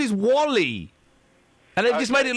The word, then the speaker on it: WALL-E: No, Wally. – What, Wall-E? – Well, yeah, but it's pronounced Wally. Why would you call a film Wall-E? That's not like, that doesn't sound like a name. You pronounce Wally.